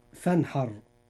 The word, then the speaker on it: فانحر: كلمة فانحر، الراء في هذه الكلمة مفخمة لأنها ساكنة وما قبلها مفتوح، فتنطق مفخمة هكذا. – فانحر.